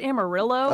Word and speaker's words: Amarillo: It's pronounced Amarillo. – Oh, my bad. – What did you call it? – Amarillo. I was, tried to do it the Spanish way.